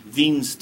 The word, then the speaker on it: Wien: Yeah, those German guys, they pronounce that W with a V, Wien's displacement law.